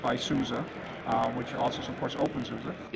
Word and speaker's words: openSUSE: And you said OpenSUSE. That's the official pronunciation? – Yeah, OpenSUSE.